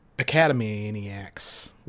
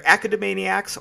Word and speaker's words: Academaniacs: I mean, it's either academaniacs or yeah, it would be academaniacs.